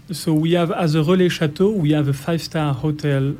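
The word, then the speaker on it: Relais Châteaux: Es una certificación que se llama relais, difícil pronunciarlo como ellos, pero es relais chateau,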